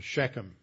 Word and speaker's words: Shechem: First place he stopped and he built an altar to God was Shechem, or Shechem as we pronounce it in English.